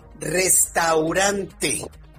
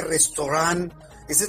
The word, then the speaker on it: restaurante: Restaurante. Quítese la duda si se le dice restaurant. Esa es la forma francesa de pronunciarlo. Pero para México y para el español bien pronunciado es restaurante.